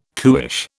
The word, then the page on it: cuish: British English